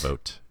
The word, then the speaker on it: Vogt: V-O-G-T. – Isn't it Vote? – Yeah. – Pretty sure Vote? It's Vote. Yeah, I think it's a silent G. – I'm going to go with because it's definitely not Vogt. – It's not Vogt? – There's no chance it's Vogt.